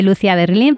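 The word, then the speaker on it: Lucia Berlin: Se escribe Lucía Berlín, pero hace poco me enteré que se pronuncia Lucía Berlín.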